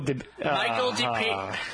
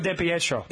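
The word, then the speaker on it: DiPietro: Say again, Michael DePietro. – Yes, there you go. – Michael DePietro.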